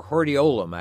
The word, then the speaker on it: hordeolum: A stye, interestingly enough, a stye is also known as a hordeolium. – Hordeolium. – I don't know if you knew that. Or hordeolum, actually.